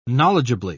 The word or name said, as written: knowledgeably